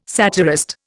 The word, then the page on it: satirist: Indian